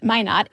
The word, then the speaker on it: Minot: It's about a county in North Dakota, which I believe is pronounced Minot.